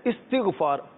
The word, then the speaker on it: استغفار: اصل تلفظ ہے استغفار,